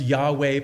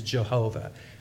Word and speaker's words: Yahweh: And it's pronounced Yahweh probably most accurately and probably most popularly in the past, at least, it was Jehovah as it was pronounced.